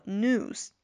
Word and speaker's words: news: 同样也是注意这个 new 这个词在美音中呢，我们可能不用读出来这个 e 这个半元音在里边，就直接是接长音 u，就是 new 就行了。比如说新闻，在美音中呢，我们就读作 news。